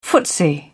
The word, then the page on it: footsie: British English